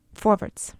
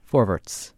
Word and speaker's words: Forverts: And how is it pronounced in Yiddish? Forverts. – Forverts.